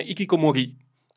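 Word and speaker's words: hikikomori: si utilizzava l'espressione Ikikomori Esattamente, quindi con l'accento sulla I per definire queste persone che si isolavano per mesi e anni.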